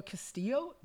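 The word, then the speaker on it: Castile: Yeah, in this specific case, Philando Castillo, did I get it correctly? – Castillo.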